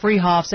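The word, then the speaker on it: Fruehauf's: They're spelled Freehoff's, pronounced Freehoff's, at 33rd and Arapahoe in Boulder.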